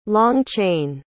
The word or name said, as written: long-chain